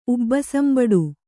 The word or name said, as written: ಉಬ್ಬಸಂಬಡು